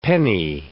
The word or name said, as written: penny